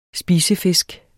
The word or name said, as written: spisefisk